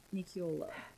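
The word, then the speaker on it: Nicuolo: Is it Nicciolo? Danielle D. Nicciolo. Nicciolo. – That sounds right.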